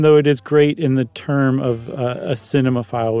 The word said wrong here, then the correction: cinephile: would use it. How about that? A cinephile. Yes. I always add an extra syllable.